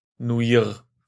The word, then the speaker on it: Pata Noir: Pata Noir. Ein genau. unvergleichlicher Kriminalfall. – Oder wie der Profi sagt, Pata Neuer. Nuir. Genau.